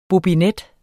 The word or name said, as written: bobinet